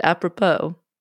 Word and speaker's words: apropos: I don't. That's how you say it, apropos.